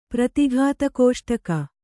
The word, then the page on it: ಪ್ರತಿಘಾತಕೋಷ್ಟಕ: Kannada; pratighāta kōṣṭaka